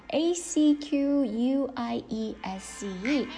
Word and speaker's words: acquiesce: 它读音，它读作 acquiesce。这个单词来看一下它怎么拼写的，a c q u i e s c e acquiesce，这是一个动词，表示什么？表示默许、默认啊，默然接受的意思。有没有觉得特别高级？顺从 acquiesce。注意一下它读音里面这个后面两个字母 ce 的话放在那边简直就是摆设啊，因为它没有发音 acquiesce 来看一下关于这个单词的例句啊。